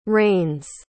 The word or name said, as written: rains